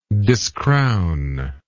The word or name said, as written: discrown